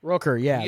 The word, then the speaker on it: Rooker: Rourke. – Michael Rourke? – Michael Rooker. – Rooker, yeah, that's how you pronounce his name.